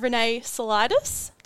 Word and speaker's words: Renee Saulitis: We have Renee Saulitis.